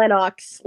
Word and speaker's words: Lennox: Lexon. – Yes. – Or Lennox. Lennox. – Yes, exactly what I said. I'm going to say lower, because I've never heard of this man. Cool. – Oh, Lennox Lewis versus...